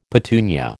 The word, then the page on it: petunia: American English